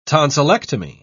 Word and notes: tonsillectomy: British English

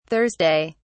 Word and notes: thursday: British English

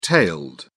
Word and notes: tailed: British English

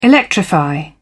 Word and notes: electrify: British English

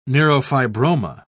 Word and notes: neurofibroma: British English

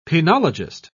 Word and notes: penologist: British English

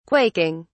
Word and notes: quaking: British English

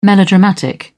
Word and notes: melodramatic: British English

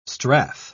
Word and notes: strath: British English

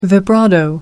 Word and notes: vibrato: American English

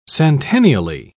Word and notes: centennially: British English

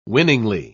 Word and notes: winningly: British English

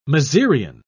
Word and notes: mezereon: British English

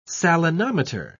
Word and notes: salinometer: British English

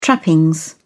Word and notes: trappings: British English

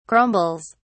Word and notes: crumbles: British English

